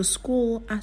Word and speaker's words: school: Đấy, ví dụ từ school, đây là CH đọc thành K.